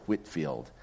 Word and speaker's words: Whitefield: It looks like it's spelled Whitefield, but he pronounced it Whitfield.